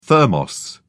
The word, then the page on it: thermos: British English